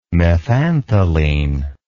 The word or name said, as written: methantheline